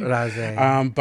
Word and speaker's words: Razé: So, well, everyone knows her as Ezra Williams, but her artist's name before that was Razé, or hopefully I'm saying that properly. – Razé.